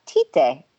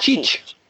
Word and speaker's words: Tite: Although, you know, I'm sorry, but according to all of my BN Sports commentators, you have Tite, not Cheech. Tite, according to BN Sports. – This pronunciation is Cheech.